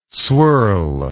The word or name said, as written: swirl